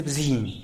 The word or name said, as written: bzin